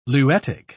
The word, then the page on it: luetic: British English